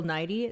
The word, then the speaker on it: Nighy: This week's Moment of Zen is the truly beautiful paparazzi photos of Anna Wintour and Bill Nighy. Is that how you say his name? – Nighy. – Bill Nye, like the science guy.